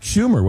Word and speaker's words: Schumer: I believe that was Chuck Schumer, was it not? – Yes. The senator's name rhymes with rumor.